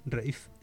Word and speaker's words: Ralph: Rave.